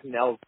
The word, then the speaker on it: Nels: Tell me, what was it like working with, I think it's Niles Jensen. I know I'm pronouncing it horribly, his name is horribly, but he's done work with Sugar Rose and also Jamie Foxx. What was it like working with him? – Yes, Nels Jensen, but you're very close, brother, very close.